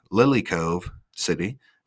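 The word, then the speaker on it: Lilycove City: And they kind of ship you right back to Lily Cove City. There you go. I butchered that earlier, but I got it now.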